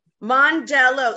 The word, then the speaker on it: Mondello: Hello, we have here today the beautiful Jennifer Steele say that again. – Mondello. – Sorry, I want to say Mondello like the. I'm so sorry about that. Jennifer Steele Mondello. Did I say it correctly? – Mm-hmm.